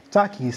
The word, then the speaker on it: Takis: How do you say them? – Takis. – Takis. Yeah, yeah, yeah.